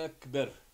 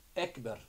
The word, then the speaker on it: ekber: Riječ ekber se izgovara kratko i brzo, bez rastezanja i odugovlačenja. – Allahu ekber. Isto, neispravno. Allahu ekber.